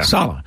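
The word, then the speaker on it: Saleh: Coach Robert Sala. I never can pronounce his name right. – Sala, yeah. – Sala.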